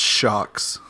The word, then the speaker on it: Sjokz: I think it's pronounced shocks. – Okay, fair yes. enough. Yeah, I'm not gonna attempt the rest of it. – Yeah, it's spelled SJOKZ for anyone wondering why we're like hesitating on this one.